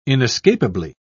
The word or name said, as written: inescapably